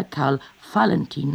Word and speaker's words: Valentin: Auer waren, so hieß es, brutal und das Kind Valentin, der sich später Karl Valentin nannte, nicht wie Wall, sondern wie Fall, entwickelte früh ausgesprochen sadistische Züge im Umgang mit Spielkameraden.